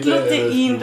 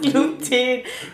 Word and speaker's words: Gluten: Das heißt nicht Glutein, du machst mich fertig, das heißt Gluten.